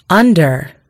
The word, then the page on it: under: British English